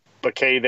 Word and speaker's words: Bacay: You see that M hyphen Bacay there and you wonder, yes, that's correct. It is silent. The M is silent.